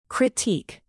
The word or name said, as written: critique